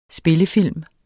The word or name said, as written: SPILLEFILM